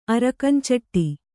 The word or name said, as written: ಅರಕಂಚಟ್ಟಿ